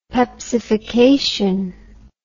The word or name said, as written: pepsification